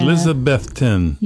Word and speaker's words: Elizabethton: Elizabethton.